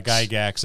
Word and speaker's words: Gygax: I know I always mispronounce his name, and I think it's Gygax. – Yeah, Gygax.